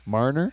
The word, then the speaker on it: Marner: Marner? – Yeah.